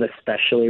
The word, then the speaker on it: especially: Technically, the correct pronunciation is with the consonant, this consonant followed by the consonant, you know, sp. But people have incorporated this KS pronunciation instead of the SP pronunciation. It's a well-known process, and that said, the actual pronunciation of especially remains with the SP sound.